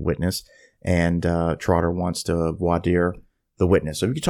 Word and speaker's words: voir dire: And I want you to talk a little bit about, and I'm going to pronounce this correct, voir dire, voir dire.